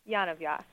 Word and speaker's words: Yonavjak: almost got my last name right. – Almost? – Yes. – Okay, would you pronounce it for us, please? – Yonavjak.